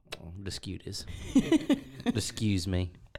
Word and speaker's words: Deschutes: Then I Googled the pronunciation. It is, in fact, Deschutes.